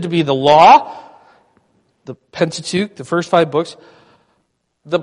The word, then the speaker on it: Pentateuch: The Pentateuch. That's great. Where did you learn that from? Yeah. I was going to say it starts with penta and rhymes with ook, but you already got it, okay?